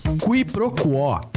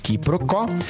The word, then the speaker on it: quiproquó: Qui pro quo. Q-U-I-P-R-O-Q-U-O com acento agudo. Qui pro quo, também informalmente pronunciada como quiproquó, é a ação de confundir uma coisa com outra, ou o desentendimento decorrente dessa confusão.